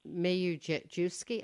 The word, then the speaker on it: Mizejewski: Thank you both, Peter Gross and David Mizejewski, I'm going to say it wrong, right? – Mizejewski, you almost got it there.